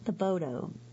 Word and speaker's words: Thibodaux: Thibodaux. Thibodaux, Louisiana.